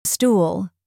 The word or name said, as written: stool